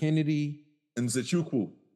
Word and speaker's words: Nzechukwu: Help me pronounce this last name. We had Kennedy. – Nzechukwu. – Nzechukwu. – Chukwu. – Cool. Got you with the W.